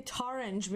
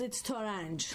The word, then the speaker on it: Torange: A lot of people say Torange because it rhymes with orange that way. But it's Torange.